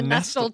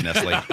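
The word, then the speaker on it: Nestlé: He said the Nestle chocolate factory. – Oh boy, you're gonna be surprised to learn that's pronounced Nestle.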